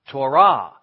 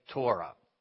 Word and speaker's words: Torah: Now, we're going to say Torah because we speak English, but if we were in speaking Hebrew, we wouldn't say Torah. We would say Torah because Hebrew tends to accent the words on the last syllable. But English tends not to do that. So in Hebrew, we say Torah, and in English, we say Torah.